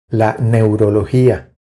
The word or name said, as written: La neurologia